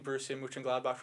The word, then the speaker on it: Borussia Mönchengladbach: So NYCFC proposes, so the team Borussia Mönchengladbach from Germany.